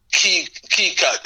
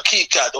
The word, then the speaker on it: mkeka: Another one of the symbols which is crucial, but they're all crucial, is it's the mat, which is also referred to as key cat, you know, my key cat, or, you know, like I said, my pronunciation, but it's spelled M-K-E-K-A, yeah, key Swahili language means the mat, you know what I mean?